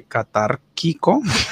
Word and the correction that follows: catártico: catartito, catártico, catártico Cat no, catártico.